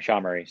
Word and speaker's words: SHAmory: Is it SHAmory? – SHAmory? You got it right the first time, SHAmory. So it's a mixture of SHA-256 and then the game of memory. So Mori. So SHAmory, smash them together.